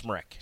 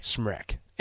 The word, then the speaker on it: Smrek: Anna Smrek. Tough name to pronounce. – Smrek. – Smrek. Anyway, S-M-R-E-K.